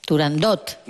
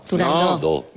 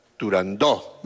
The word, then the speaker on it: Turandot: Turandot. – No. – Turandó. – No. No se dice, no se debe pronunciar Turandot. – Ah, no, Turandó. – La ópera se titula Turandó.